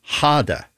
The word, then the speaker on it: Gade: The composer is Niels Wilhelm Gade, or Gade, if you wish, in English. It's spelled G-A-D-I, or G-A-D-E, beg your pardon.